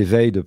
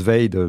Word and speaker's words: Vade: Voilà, donc on le prononce Vade, mais ça fait aussi écho à invade ou evade, enfin s'évader.